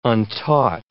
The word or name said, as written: untaught